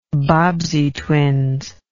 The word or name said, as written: Bobbsey Twins